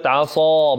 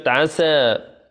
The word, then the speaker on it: عصى: هنا قبل أن التلاوة انتظروا، هنا لابد من بيان كلمة عصى بالصاء، ولا نقول يعني لا تشدد عليها ولا تستعليها فتكون تشابه لكلمة عسى، عسى. فلابد من نطق الصاد صحيحة وإلا يغير هنا المعنى.